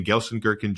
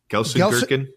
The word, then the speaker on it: Gelsenkirchen: If you say it with a German accent, it probably sounds more like the actual city, but my American accent is Gelsenkirchen.